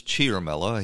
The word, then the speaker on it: Ciaramella: It's Eric Ciaramella. I've been pronouncing it Ciaramella. There's no H there. I don't know, where the H sound comes from, but I'll take everybody's word for it. It's Ciaramella.